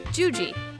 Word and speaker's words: Juji: I'm your host, Juliana Blaine, but you can call me by my nickname, Juji. It rhymes with Fuji, like the apple.